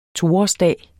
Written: toårsdag

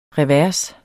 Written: revers

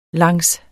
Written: langs

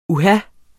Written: uha

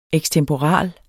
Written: EKSTEMPORALE

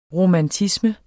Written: romantisme

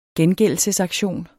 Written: gengældelsesaktion

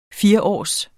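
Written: FIRÅRS

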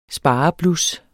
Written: spareblus

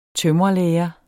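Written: tømrerlære

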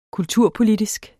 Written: kulturpolitisk

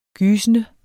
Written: gysende

